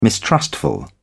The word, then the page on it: mistrustful: British English